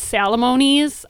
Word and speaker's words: Salamone's: It's actually pronounced Salamone's. I always tell my husband it rhymes with alimony.